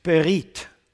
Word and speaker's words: berit: Actually, in Hebrew, it's berit. Berit.